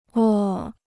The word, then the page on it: 窝: wō